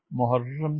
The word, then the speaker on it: محرم: اس لیے اسلامی مہینے جو اب آئندہ ماہ محرم سے شروع ہو رہے ہیں ان کے تلفظ ہمیشہ درست کرنے چاہیے۔ ان مہینوں میں پہلا مہینہ محرم کہلاتا ہے اور را کی شد کے ساتھ